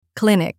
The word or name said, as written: clinic